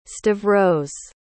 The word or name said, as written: stavros